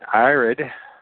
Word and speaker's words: Irad: And Irad, like Iraq, but with a D at the end of it, Irad, was born unto Enoch.